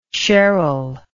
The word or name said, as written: sheryl